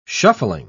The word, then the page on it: shuffling: British English